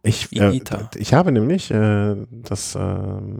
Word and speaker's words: Higuita: Higuita? Das I, das H spricht man nicht mit Higuita. – Ja, aber wo ist denn das U? – Ja, das ist auch stimmlos.